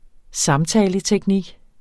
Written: samtaleteknik

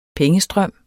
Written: pengestrøm

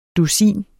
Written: DUSINET

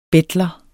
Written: BETLEREN

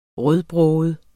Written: rødbroget

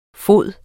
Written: fode

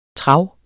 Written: trav

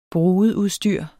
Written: brudeudstyr

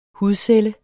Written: hudcelle

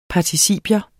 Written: participium

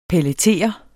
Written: pelletere

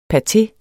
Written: pateer

